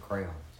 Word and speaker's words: crayon: Why are you making it two syllables? Why is it crayon? – Isn't it a two syllable? – It's not. Google it. I don't care. It's not. It's crayon. You don't break it down like that.